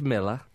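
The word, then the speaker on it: Milla: I say Roger Meir because that's how Barry Davis would pronounce it. – Oh. – I know it's Miller.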